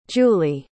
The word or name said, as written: julee